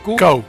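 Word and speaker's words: Coe: We hebben Sebastian Koe. Zeg ik dat goed? – Koe. – Koe.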